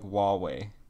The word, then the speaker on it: Huawei: It's like H-E-I-U. – Oh, yeah, I know what you're talking about now. – Yeah, it's called... It's spelled really fucked up, but it's called... It's pronounced like Huawei.